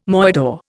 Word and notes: moidore: Indian

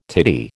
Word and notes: titty: American English

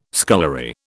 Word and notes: scullery: British English

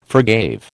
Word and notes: forgave: American English

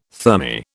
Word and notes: thermae: British English

